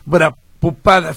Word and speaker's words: Prabhupada: Prabhupada, sí.